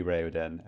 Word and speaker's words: Raoden: So yeah, I also was hoping it would be Raoden, and it's good to know. – Yeah, so anything that has names influenced by aeons, the vowels are all pronounced separately.